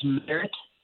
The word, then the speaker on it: Merritt: That's Merit Coaching Group, and our last name is spelled M-E-R-R-I-T-T, so Merit Coaching Group on Facebook.